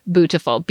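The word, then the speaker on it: Oh Sew Bootiful: And the ones we like are from this company called Oh So Bootiful. – Can you please spell the phonetically complicated yes. – So Beautiful? – So it's O-O-H, so S-E-W, Bootiful, B-O-O-T-I-F-U-L.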